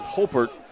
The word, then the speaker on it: Haupert: That was kind of the illustration right there by quarterback Luke Hopert, H-A-U-P-E-R-T, but pronounces it Hopert,